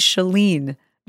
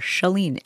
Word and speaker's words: Chalene: It's Shalene. It rhymes with Celine. And the first couple syllables, shuh, like shut the door, Shalene.